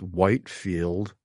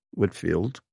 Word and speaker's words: Whitefield: it's spelled as if it is pronounced Whitefield, Whitefield, but it's pronounced Whitfield, in spite of how it's spelled.